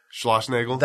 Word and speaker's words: Schlossnagel: And your head coach there, how do you say his name? – Schlossnagel. – That's the one.